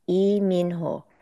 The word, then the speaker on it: Lee Min Ho: So his actual name is Lee Min Ho. So it's really tough because the mostly the Korean surname that is anglicized as Lee is E, E.